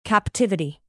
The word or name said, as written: captivity